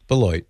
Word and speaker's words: Beloit: That's not how you'd say it in French, but how do they say it in Wisconsin? – Beloit.